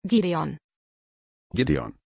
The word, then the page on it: gideon: German